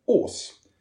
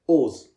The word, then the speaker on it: Wrs: Men det är så man uttalar hans eh, artistnamn som stavas WRS.